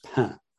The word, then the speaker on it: pain: and pain in France, where it's pronounced pan.